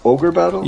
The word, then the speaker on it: Ogre Battle: And I also put Org Battle 64. I don't know if you've ever played that. It's a person of lordy caliber. – Isn't it pronounced Ogre Battle? – Yes, it is.